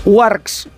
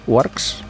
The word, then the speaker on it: UARX: Warx, ¿se dice así? Warx, U-A-R-X. – Es española, ¿eh? – Bueno, pero yo es que... – U-A-R-X. – U-A-R-X, vale.